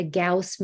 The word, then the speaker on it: Gauss: And Gina would also like to know, I hope I'm pronouncing this wrong, right, G-A-U-S-S-I-N, Gaussian? A Gaussian meter? – Oh, a Gauss meter.